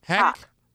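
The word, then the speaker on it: Haqq: How do you pronounce her last name? – Hawk. – Hawk? – Hawk.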